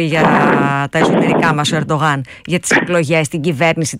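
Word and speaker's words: Erdoğan: Μέχρι να έρθει ο κύριος Ερντογάν, που είναι η σωστή προφορά με τα τουρκικά της Ανατολίας,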